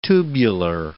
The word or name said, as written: tubular